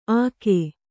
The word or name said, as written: ಅಗೆ